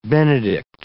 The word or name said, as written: benedict